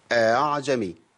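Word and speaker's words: ءأعجمي: يبقى بسهل الهمزة بين الهمزة وأصل حركتها. وإحنا عندنا ثلاث كلمات تانية يضافوا الكلمة ديت لينا فيهم وجه تسهيل في بعض طرق حفص اللي هم الله الآن الذكرين. خلاص يبقى نطقها الصحيح ايه؟ أعجمي أعجمي أعجمي.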